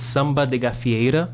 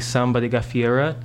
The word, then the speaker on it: Samba de Gafieira: To help you again with the pronunciation, I'm going to say it in Portuguese, it's Samba de Gafieira. But you can say Samba de Gafieira. That's if that helps kind of anglicize it a bit.